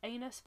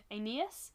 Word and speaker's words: Aeneas: Now, the spelling is telling me this is pronounced anus, but I don't want to say it's pronounced anus. Let's just say it is. Aeneas would escape to find a new nation in Rome.